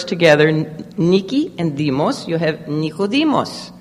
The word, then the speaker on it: demos: And then it also consists of the word demos. In Greek, the D is not pronounced like D. It's a hard TH. Demos.